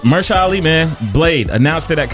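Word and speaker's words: Mahershala Ali: On the movie tip real quick, because I know we have movies, Marshala Ali, am I saying his name right? – Yes.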